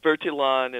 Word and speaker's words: Bertillon: Yeah, but it, it, depending on who's pronouncing it, Bertillon, Bertillon, but Bertillon is probably the most correct pronunciation.